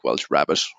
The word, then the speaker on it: Welsh rarebit: but nobody is honestly sure why it's called Welsh rarebit. There's kind of lots of different rumors doing the rounds, but it's kind of the way they pronounce it, it definitely sounds more like Welsh rabbit, which is definitely funny.